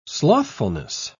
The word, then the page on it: slothfulness: British English